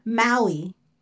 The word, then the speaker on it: Maui: But okay, next one, Maui. That was the Rock's character. – M-A-U-I? – Yes, spelled like Hawaii. Okay, the next one, Simba. – S-I-M-B-A.